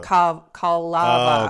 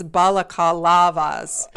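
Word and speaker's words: balaclava: That's crazy that it's baklava, because baklava. – Balakalava. – Oh, okay, because I was like baklava. I can't pronounce dessert. – It is baklava, and then it's balakalavas.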